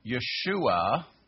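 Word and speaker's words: Yeshua (ישוע): The Greeks wanted you to pronounce the name of the Messiah, which is, by the way, Yeshua. And let me do that in Hebrew for you. Which is a Yod, a Shin, a Vav, and an Ain. Yeshua. That's his name in Hebrew.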